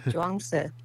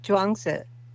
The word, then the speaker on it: Chuang Tzu: I'll have my wife say it properly. – Chuang Tzu. It's Chuang Tzu.